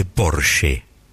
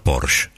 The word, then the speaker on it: Porsche: La marca es alemana y se dice Porsche. No Porsche. – Porsche. – Esa E final en alemán. Se pronuncia.